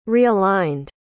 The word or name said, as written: realigned